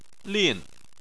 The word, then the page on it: lin: 4